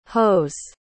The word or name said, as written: hoes